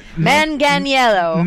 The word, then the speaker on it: Manganiello: Wait, did I say it right? – No one says it right. – Not even Joe. – No, he didn't even know how to say it. Manganiello. – Manganiello. Thank you. Manganiello. For some reason, I want to say Manganiello, but Manganiello.